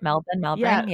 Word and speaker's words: Melbourne: And my favorite, I think, was Melbourne. Is that how I pronounce it right? Melbourne.